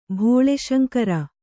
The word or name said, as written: ಭೋಳಾಶಂಕರ